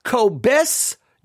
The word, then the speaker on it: Kobes Du Mez: K-O-B-E-S. It's Kristin, K-O-B-E-S. Then it's D-U-M-E-Z. Kristin, K-O-B-E-S-D-U-M-E-Z. Now, the best I can figure out from the author herself is she says, don't worry about the pronunciation. It's impossible, but supposedly it's Kristin Cobis-Dume.